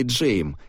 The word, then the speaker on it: Джейм: Оттуда он его взял и требует, чтобы его произносили Джейм, как Джеймс, только без «с».